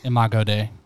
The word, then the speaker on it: Imago Dei: So the image of God is it pronounced Imago Dei? – Imago Dei. – Imago Dei, okay.